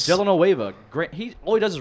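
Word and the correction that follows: Dellavedova: Delanueva. Let's just go back to that. Delanueva. – Did I say it wrong? I don't even know. – Delavadova. – Delavadova. – Swing and a miss.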